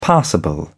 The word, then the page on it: passable: British English